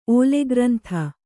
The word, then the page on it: ಓಲೆಗ್ರಂಥ: Kannada